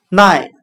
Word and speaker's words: night: 我们可以用谐音法来记忆这个单词 night，它的发音很像汉语的耐它，忍耐的耐。